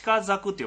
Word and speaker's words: 鹿柵: そうですね、あとと書いてますね、あと、高山植物を保護するためあちこちで鹿の柵、鹿柵って読むんですよね、鹿の柵が設けられておりました。